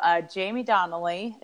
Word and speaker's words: Jaime Donally: So Jaime Donally, it's J-A-I-M-E and then D-O-N-A-L-L-Y.